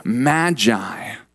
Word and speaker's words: magi: Well, Matthew uses the Greek word magoi for them. We pronounce it magi.